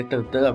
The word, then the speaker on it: Tintin: Ah, eu gostaria de recomendar um filme bem recente dele, que é o Tintin, o Tantan, né, que é belga. Então seria Tantan a pronúncia.